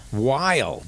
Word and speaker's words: Weil: Weil. Dan Weil. That's W-E-I-L for you dummies out there.